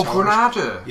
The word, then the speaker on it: Granada: Is that how you pronounce it? Is it Granada or Grenada? – I've honestly never heard of it. It's like a television, Granada. – Oh, Granada. – Yes.